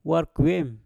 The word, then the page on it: war kwem: mpur